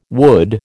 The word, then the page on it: wood: American English